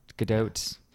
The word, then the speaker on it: Gadot: I always say her name on Gadot. – It has a hard T. I know that. – Gadot.